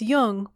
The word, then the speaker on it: Jung: Be sure to pronounce Jung with a hard J.